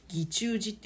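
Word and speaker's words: 義仲寺: 義中寺って読むんですけど、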